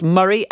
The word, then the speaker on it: Murray: Thank you for letting me know that Murray is apparently pronounced Murray, as in Andy Murray.